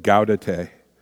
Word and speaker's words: Gaudete: There's people pronounce it different ways, but Gaudete.